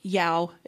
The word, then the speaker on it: JAOO: It stands for Java and Object Orientation Conference, JAOO, which is pronounced YOW if you're Danish.